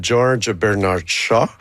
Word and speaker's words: George Bernard Shaw: Ben, l'inspiration vient de la pièce de George Bernard Shaw, que j'ai prononcé comme il faut.